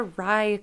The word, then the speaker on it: RIE: No, it was it's a Rye class. So it's spelled R-I-E, and it's so I heard about it from Dre Vermeulen.